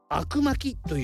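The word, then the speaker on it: 灰汁巻き: 悪巻きというふうに言われています灰の汁と書いて悪と読みます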